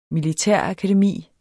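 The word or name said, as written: MILITÆRAKADEMI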